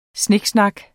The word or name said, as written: snak